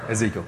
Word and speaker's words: Ezekiel: Oh, sorry, Ezekiel. Thank you, Scott. Yeah, Ezekiel 33. It's right on the board. I said it wrong.